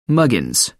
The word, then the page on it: muggins: American English